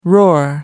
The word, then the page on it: roar: American English